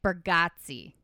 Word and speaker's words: Bargatze: So recently I saw this, my number four, Nate Bargatze. Okay. I looked it up how to say it because it is an A, but it's pronounced Getsy.